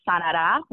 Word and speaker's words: Sanara: So Sanara was born, which Sanara comes from the Spanish word Sanara, with the accent on the A, which means you will heal.